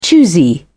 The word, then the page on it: choosy: British English